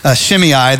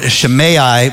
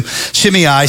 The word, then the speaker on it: Shimei: and his name was Shimei. That's how we pronounce it in the English. I think a Hebrew pronunciation would be more Shimei, but let's just stick with what we're used to. Shimei, son of Gera.